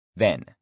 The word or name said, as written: then